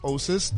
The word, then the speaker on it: Oasys: I go by the name of Osis, the Flosis. Now, it's Oasis, but it's pronounced with a silent A and it's spelled with a Y.